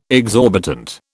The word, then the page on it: exorbitant: British English